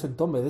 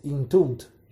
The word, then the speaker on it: Entombed: No, aquí en España, bueno, en el Estado, no pronunciamos los nombres de las bandas. – Ah, no. – No, no decimos, porque no es Entombed, es Intombed.